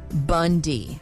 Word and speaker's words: Bounde: The issue is that our last name is spelled B-O-U-N-D-E, pronounced Bundy.